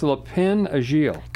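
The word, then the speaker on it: Lapin Agile: So it's Le Pin Agile. – Correct.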